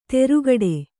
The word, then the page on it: ತೆರುಗಡೆ: terugaḍe